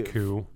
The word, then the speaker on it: coup: In their new overseas home, an American family soon finds themselves caught in the middle of a coup, coup. – I know, I always do that. I hate silent letters.